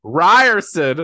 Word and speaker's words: Riorson: The rest would kill her just for being her mother's daughter, like Xaden Riorson. The most powerful and ruthless wing leader, I probably said Riorson wrong. How do you say it? – Ryerson! – Xaden Ryerson.